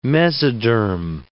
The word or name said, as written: mesoderm